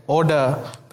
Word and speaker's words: ODA: We call it ODA, which rhymes with Yoda, is our inside joke.